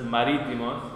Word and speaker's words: marítimos: Si no saben la pronunciación, es difícil, pero la pronunciación es marítimos, entonces en la primera I.